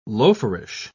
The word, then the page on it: loaferish: British English